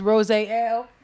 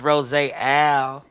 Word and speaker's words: Rosé Ale: I'm drinking this Cidergeist Bubbles Rosé Ale. And I'm still drinking that. I've been drinking that since forever. – She ain't said it right. It's Rosé Ale.